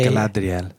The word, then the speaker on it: Galadriel: Galadriel.